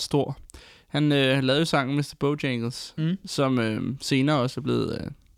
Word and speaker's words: Mr. Bojangles: Mr. Bojangles.